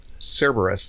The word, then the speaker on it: Cerberus: Of course, the most famous hellhound is mostly, and I always say this name wrong, is it Cerberus from Greek mythology? – Cerberus, see?